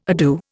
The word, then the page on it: ado: Indian